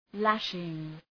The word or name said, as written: lashing